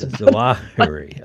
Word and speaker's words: Zawahiri: The message to everyone who followed, I guess you pronounce it Zawahiri, is that how you pronounce it? – I think the accent is on the second syllable, but that's Zawahiri.